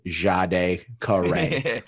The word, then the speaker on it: Jade Carey: And also, just for your future reference, the name is pronounced Jade Carey.